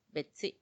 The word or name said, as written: betsi